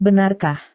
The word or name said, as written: benarkah